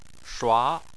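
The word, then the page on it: shua2: tone 2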